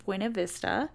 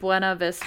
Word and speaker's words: Buena Vista: Anyways, so in 1880, in an area 20 miles outside Buena Vista, people. – Buena? That's how the locals say it.